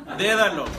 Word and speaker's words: dédalo: Dédalo, con acento en la E.